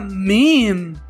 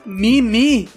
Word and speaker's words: meme: O primeiro caso da palavra meme, que se pronuncia meme. – Meme. – E não mimi.